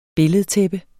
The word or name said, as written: billedtæppe